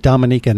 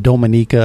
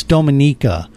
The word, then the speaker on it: Dominica: And by the way, that's how you say it, Dominica, not Dominica, or not Dominica, which is what all the mainstream media said last week on the news. It's Dominica.